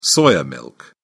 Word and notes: soya milk: British English

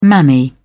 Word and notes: mammy: British English

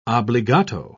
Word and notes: obbligato: British English

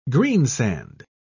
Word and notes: greensand: British English

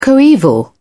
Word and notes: coeval: British English

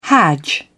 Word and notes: hadj: British English

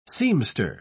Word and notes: seamster: British English

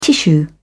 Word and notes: tissue: British English

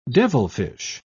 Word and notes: devilfish: British English

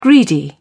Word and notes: greedy: British English

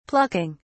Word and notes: plucking: British English

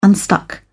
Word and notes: unstuck: British English

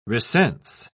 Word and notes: recense: British English

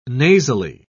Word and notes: nasally: British English

